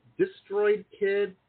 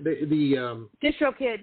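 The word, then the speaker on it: DistroKid: Is it Destroyed Kid? Destroyed Kid? – Distro Kid. Yeah.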